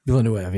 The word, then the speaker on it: Villeneuve: Villeneuve, yeah.